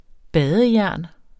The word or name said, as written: badejern